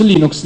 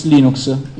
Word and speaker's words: Linux: Voi avete sentito tutti quanti il file audio di Linux che dice This is Linux Torvald, I would like to pronounce Linux Linux.